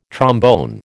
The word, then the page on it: trombone: American English